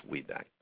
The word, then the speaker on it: Wiebeck: Well, it's Wiebeck, but Wiebeck. I answered either. That's fine. It's a German. It's W-I-E, and it's pronounced Wiebeck.